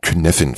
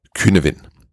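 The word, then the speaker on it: Cynefin: Du hörst wahrscheinlich häufig Künevin Framework. Dabei heißt es Künevin. Das nur so nebenbei, falls du mal beide Betonungen irgendwo hörst.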